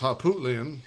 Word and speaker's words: Harpootlian: Yeah, Todd, I believe it's pronounced Harpootlian.